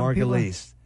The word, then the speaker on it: Margulies: Margulies? – You're throwing all these names out. – Margulies.